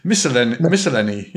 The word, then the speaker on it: miscellany: Do you know how to say it? – It's miscellany. – Miscellany. – Miscellany. There we go.